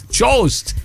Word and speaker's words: Jost: So, and how do you pronounce Jost? It's Jost. – Jost.